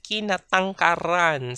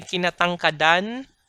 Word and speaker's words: kinatangkaran: Kinatangkaran si Jonathan. We don't say kinatangkadan si Jonathan. We say kinatangkaran si Jonathan. In this case, the D becomes a rhotic R.